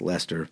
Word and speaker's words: Leicester: L-E-I-S-T-E-R, but pronounced Leicester, and led by Robin Gibson, who has made a bunch of solo singles I've played on this show in the interim.